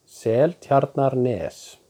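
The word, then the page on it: Seltjarnarnes: Icelandic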